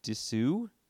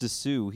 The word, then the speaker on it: Disu: Dis- nope, it was just. I'm not saying it right, but it's Dylan Disu. Just Disu.